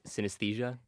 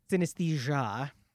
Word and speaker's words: Synesthesia: It's called Synesthesia. I assume I'm pronouncing that right. – I pronounce it Synesthesia. – Synesthesia.